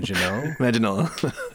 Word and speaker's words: Manganiello: Manganiello.